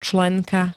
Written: členka

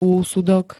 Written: úsudok

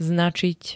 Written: značiť

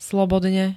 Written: slobodne

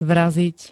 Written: vraziť

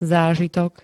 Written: zážitok

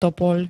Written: topoľ

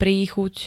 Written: príchuť

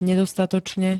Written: nedostatočne

